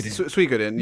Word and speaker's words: Suikoden: How do you say that? The Suikoden. – Yes, Suikoden. Yeah, Suikoden's right.